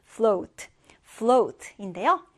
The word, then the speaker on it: float: 이 단어도 발음 주의하는데, O 발음으로 해줍니다. float, float인데요.